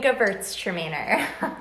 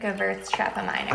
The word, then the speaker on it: Gewurztraminer: 2018 Gewurztraminer. – Gewurztraminer? – Gewurztraminer. – I always wondered how that was... – Gewurztraminer.